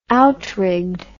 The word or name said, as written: outrigged